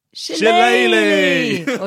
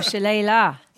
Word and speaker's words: shillelagh: Tell you the number of people who have said their correct pronunciation is shillelagh or shillelagh, depending on where you're from.